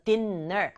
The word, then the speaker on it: dinner: N이 두 번 있지만 dinner 이렇게 발음하지 않아요. 영어에서는 똑같은 글자 두 번 오면 같은 소리잖아요. 소리 한 번 맞네요. 그래서 dinner 아니고 dinner, dinner 이렇게 하면 되고 What's for dinner?